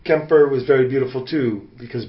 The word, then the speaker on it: Quimper: Well, no, Kemper is how they pronounce it. – Okay. Yeah, because of Breton.